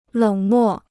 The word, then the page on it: 冷漠: lěng mò